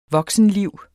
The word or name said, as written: voksenliv